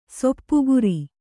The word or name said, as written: ಸಾಪುಗಾರ